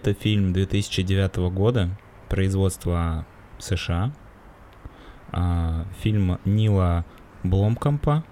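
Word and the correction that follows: Blomkamp: Фильм Нила Бломкампа. – Покажи, как это пишется. – Ну, Бломкамп. Нил Бломкамп. Бломкамп.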